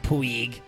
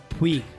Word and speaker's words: Puig: No, Puig. – Puig? – No, Puig.